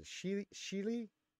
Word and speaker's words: Shili: She was discovered on her homeworld of, and I always say this wrong, it's a Shili, by Jedi Master Plo Koon, who brought her to the Jedi Temple on Coruscant to receive her Jedi training.